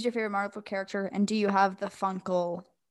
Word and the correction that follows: Funko: Is that the right word? – Funko.